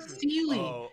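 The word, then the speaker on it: Seelie: Sealy.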